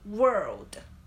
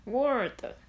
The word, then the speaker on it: world: 我刚才发现一个大家很容易发音比较不准确的，就是这个字，就是 world，世界。对、嗯、 world，大家很容易发成怎么样呢？Word。